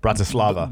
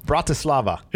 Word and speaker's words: Bratislava: Bratislava. – The local no. – Bratislava.